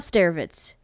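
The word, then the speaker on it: Starewicz: Another pioneer worth mentioning is Vladislav Staretz. That's my very American way of pronouncing his name.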